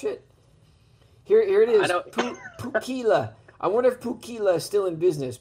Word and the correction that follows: Pookela: Pookela. That's not how you say it. It's Pukela. Pukela in Hawaii.